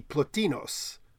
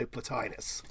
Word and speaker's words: Plotinus: And in Greek, it'd be Plotinos. And somehow we got the correct correct stress accent in Latin, but the wrong I. It should be Plotinus, really. But no one pronounces it Plotinus. Everyone pronounces it Plotinus. So if you're at a big academic or philosophical conference, I would say Plotinus.